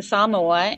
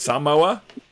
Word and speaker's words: Samoa: You see how we both said it wrong? Yep. Anyway, Samoa, right? Samoa? – Yes. Right, Samoa.